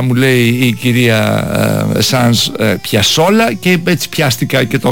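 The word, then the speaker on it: Piazzolla: Αυτό είναι, πώς προφέρεται, Ελ Τσόκλο. – Και Άστορ Πιασόλα. – Σωστότατα. – Μισό λεπτό. Το άκουσα να το λέει. Εγώ τον έλεγα Πιατσόλα τόσο καιρό.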